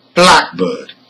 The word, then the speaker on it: blackbird: Let's hear number two, blackbird. The same applies here. Black is stressed and it functions as a noun.